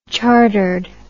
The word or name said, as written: chartered